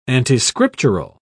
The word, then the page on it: antiscriptural: British English